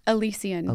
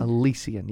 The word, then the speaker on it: Elysian: And how do you say the perfume brand? – Elysian. – Elysian. Yeah. That's right. – E-L-Y-S-I-A-N. I used to mispronounce it, for sure.